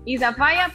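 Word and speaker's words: Isafyah: Isafaya, pues significa fuego en jamaiquino.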